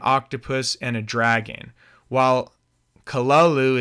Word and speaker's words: Cthulhu: Lovecraft, and Lovecraft did pronounce it Cthulhu. A lot of people pronounce it a different way, but that's how it's pronounced.